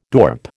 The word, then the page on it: dorp: American English